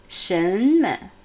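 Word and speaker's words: Shenme (什么): Also, ni mai shen me.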